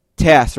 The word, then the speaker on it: Tass: Tass, right? That's how you say it? Yeah, ass with a T. Tass.